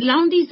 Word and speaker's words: Lowndes: Is Lowndes the right way to say it? – It's a jolly good attempt. It's a strange name, and the E is silent, so you actually say it Lowndes. – Lowndes. – You lose the D and the E.